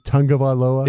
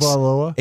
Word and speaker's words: Tagovailoa: Is it Tagovailoa? – It is. That's how you pronounce it. Yes. 856. Tagovailoa.